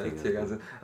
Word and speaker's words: Tegernsee: Und danach kommt Tegernsee. Ist das richtig ausgesprochen? Ja, Tegernsee.